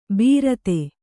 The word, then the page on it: ಬೀರತೆ: Kannada